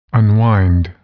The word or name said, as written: unwind